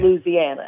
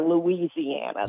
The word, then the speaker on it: Louisiana: If you live in or have lived in Louisiana, it's okay. Louisiana. All it's right. never Louisiana. That's how they pick you as an outsider.